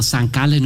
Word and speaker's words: St. Gallen: Ja, für alle im Bhutan oder irgendwo, wo es hört, so spricht man St. Gallen aus.